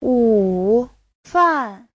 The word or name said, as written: wu3fan4